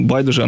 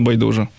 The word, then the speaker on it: байдуже: І добре, а от бачите, знайшли відповідь, чому байдуже, а не байдуже. – Чому? – А тому, що отакий я безграмотний, мабуть. – Ні, справа в тому, що великий тлумачний словник української мови дає абсолютно два рівноправні наголоси у цьому слові, тому то я і запитую.